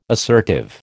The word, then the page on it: assertive: American English